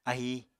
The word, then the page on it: ahi: Apatani